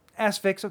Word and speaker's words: Asphyx: And then you have the Aphix, played by Zachary Hing. – Asphyx, okay. – Asphyx, because it's supposed to be like asphyxiation.